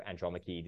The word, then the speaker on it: Andromache: what I just say, you know, Andromache, this is how you pronounce it, and it comes from the story of the Trojan War.